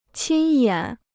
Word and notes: 亲眼: qīn yǎn

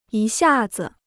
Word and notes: 一下子: yī xià zi

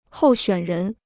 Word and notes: 候选人: hòu xuǎn rén

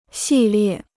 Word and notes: 系列: xì liè

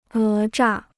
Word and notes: 讹诈: é zhà

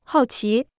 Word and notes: 好奇: hào qí

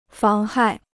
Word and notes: 妨害: fáng hài

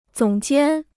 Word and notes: 总监: zǒng jiān

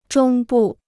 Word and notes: 中部: zhōng bù

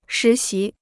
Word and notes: 实习: shí xí

